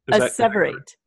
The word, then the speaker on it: asseverate: Am I pronouncing it right? Asseverate? – Asseverate.